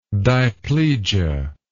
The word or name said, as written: diplegic